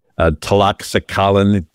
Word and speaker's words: Tlaxcalan: Well, the Toxalan's tribe that had been persecuted. And I know I'm not pronouncing that right. Tlaxcalan, T-L-A-X-C-A-L-A-N.